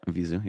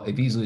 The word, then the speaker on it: Evisu: In fact, if you remember, I mean, they're still around, the brand Evisu, Vizu, yep. – which is one of the original Japanese denim brands. Well, Evisu is just the way a Japanese would pronounce Levi's. So,